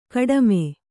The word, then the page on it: ಗುಡುಮಿ: guḍumi